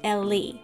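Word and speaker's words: Elle: Yes, it's spelt like L, but pronounced Ellie.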